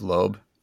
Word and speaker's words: Loeb: Oh, Jeff Loeb. – L-O-E-B. Would you say that's Loeb? – Jeff Loeb. – Yeah, you're right. Loeb.